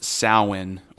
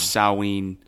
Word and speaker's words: Samhain: It's pronounced Samhain, or sometimes Samhain. – Samhain. – I like Samhain. It's spelled, like I mentioned last podcast, it's spelled like it would be pronounced Samhain. So if you see that, it's actually Samhain.